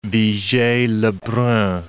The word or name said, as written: Vigee-Lebrun